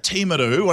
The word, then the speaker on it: Timaru: In Timaru, one of the most beautiful towns in New Zealand, yes, that's how you pronounce it, I'm pronouncing it properly.